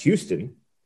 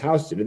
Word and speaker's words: Houston: In Texas, it's pronounced Houston, which is a city in Texas. In New York, the street is pronounced Houston.